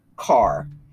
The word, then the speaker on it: Kaur: And the author, let me spell it for you, is R-U-P-I, and last name is K-A-U-R, R-U-P-I, which I believe is Rupi or Rupai, and the last name is K-A-U-R, which I believe is pronounced car.